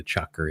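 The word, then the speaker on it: chukar: And I kind of thought that that's what it was, but that's not what a chucker is at all.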